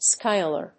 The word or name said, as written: skyler